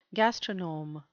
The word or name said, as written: gastronome